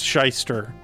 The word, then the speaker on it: Shyster: I believe that's how it's pronounced, Shyster, but like shy as an S-H-Y-ster. And we're going to hear Buttered Toast off this record, a little bit of a longer track.